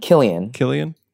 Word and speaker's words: Cillian: The how do you say his name? Cillian? – I think Killian. – Killian Murphy. That's how you say.